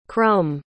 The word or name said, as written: crum